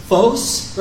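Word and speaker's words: phos (φῶς): The true light, okay, so the Greek word here in a Prescott Valley pronunciation is phos, right? Phos is how I would say it.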